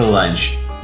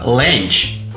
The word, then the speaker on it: ланч: Следующий прием пищи у американцев называется ланч. Русская печать и все ее читающие ошибочно произносят это слово «ленч».